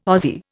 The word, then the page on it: Ozzie: Indian